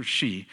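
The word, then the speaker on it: Hsi: This is from a pastor in China, Pastor She, which is spelled H-S-I, I guess. So it's Pastor She.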